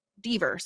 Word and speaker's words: Deaver: I'm so curious to see how Caitlin, is it Caitlin Denver's Caitlin? I want to get her name right. Caitlin Deaver. Sorry, Deaver.